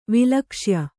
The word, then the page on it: ವಿಲಕ್ಷ್ಯ: vilakṣya